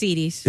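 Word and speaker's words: Edy's: It might be pronounced Eddie's, but whatever. – Is it Eddie's? – It's Edie's. No, it's Edie's.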